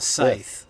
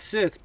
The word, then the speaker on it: Scythe: You said Scythe. – And I said Sith because that's what the bartender said.